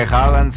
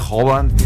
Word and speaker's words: Haaland: Incluso, por ejemplo, no se pronuncia Haaland, se pronuncia Hovan.